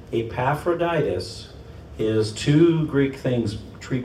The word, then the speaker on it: Epaphroditus: Meanwhile, I thought I should send Epaphroditus.